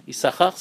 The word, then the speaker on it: Issachar: Vous savez qu'il y a deux signes dans Issachar. Un qu'on prononce, un qu'on prononce pas.